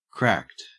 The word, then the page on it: cracked: Australian English